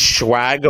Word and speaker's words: swag: Is swag a word? – How do you say the word? – Yeah. – Swag. It's SWAG, not SHWAG.